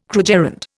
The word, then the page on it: krugerrand: Indian